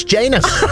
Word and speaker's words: Janus: It rhymes with anus. It's Janus.